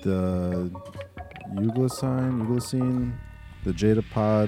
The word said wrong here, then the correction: Euglossine: Euglossine. – Euglossine.